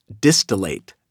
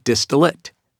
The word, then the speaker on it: distillate: The word that I learned how to properly pronounce through narrating this book is the word distillate. I have for years used that word and pronounced it distillate. And it turns out, thanks to my wonderful, wonderful director, Andrea Kaufman, who taught me, it is pronounced distillate, not distillate.